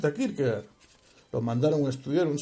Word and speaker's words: Kierkegaard: En este post-scriptum, nos dice, de primeras, ya les he leído la definición con la que concluye, en este post-scriptum nos dice Søren Kierkegaard, o Kierkegaard, como se mejor pronuncia en danés,